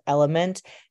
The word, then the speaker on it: LMNT: My most recently preferred electrolyte supplement is Element. It's spelled L M N T if you look it up. The reason that I really prefer Element is because because of the ratio of electrolytes and the saturation density of electrolytes alongside how the formula dissolves.